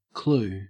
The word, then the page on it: clue: Australian English